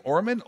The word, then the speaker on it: Orman: Susie Ullman. Is that how you say her name? Orman.